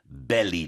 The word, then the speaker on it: belly: So first we have ballet dancer. Ballet. Then we have belly dancer. Belly. So you can hear the difference in the sounds of the vowels.